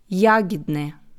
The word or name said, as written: Jahidne